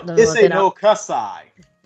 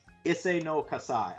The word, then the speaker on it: Issei no Kassai: It's called Issei no Kakai? No, Issei no Kasai. Issei no Kasai.